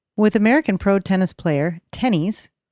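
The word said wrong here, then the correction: Tennys: Pronounced tennis. – You see how you struggled, though? Because it was... Anyway, it's pronounced tennis.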